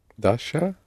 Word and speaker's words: dacha: Dacha. – Not quite. – Dacha? – Ч, yes, the dacha. – Dacha. It's the word dacha. And you do spell it in Russian with the letter ch.